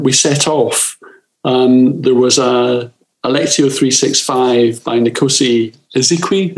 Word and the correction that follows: Izwe Nkosi: Is that how you pronounce it? Izwe Nkosi, yeah.